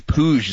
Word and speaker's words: Puig: I think it's Puig is how you say it, actually. – Oh, is that how you say it? Puig? – Yeah, I believe it is Puig. I think he's Spaniard or something like that.